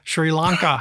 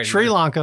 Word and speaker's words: Sri Lanka: Sri Lanka.